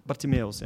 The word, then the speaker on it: Bartimäus: das Bartimäus, habe ich richtig ausgesprochen? Bartimäus, ja.